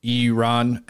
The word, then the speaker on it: Iran: Now, I have been told the proper way to pronounce Iran is Iran, and I'm going to truthfully tell you, I don't give a crap.